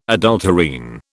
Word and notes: adulterine: British English

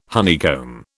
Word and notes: honeycomb: British English

